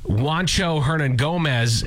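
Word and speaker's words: Juancho Hernangómez: But he discovers this kid, Juancho Hernan Gomez is the guy who plays him.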